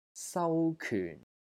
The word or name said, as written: SAU2 KYUN4